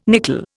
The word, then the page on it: nickel: Indian